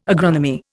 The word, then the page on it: agronomy: Indian